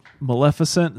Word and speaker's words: Maleficent: But the characters are in character, like, was it Maleficent? Is that how you say it? – Sure. I think that's right.